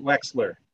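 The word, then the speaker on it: Wechsler: who interestingly was affiliated with Weichler, who was one of the big giants in. Do I have that right? Am I pronouncing that right? – Herbert Wexler. – Yes. Wexler.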